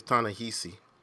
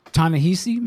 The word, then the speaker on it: Ta-Nehisi: It's Ta-Nehisi. – Ta-Nehisi, my bad.